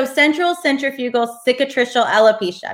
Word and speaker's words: central centrifugal cicatricial alopecia: So central centrifugal cicatricial alopecia.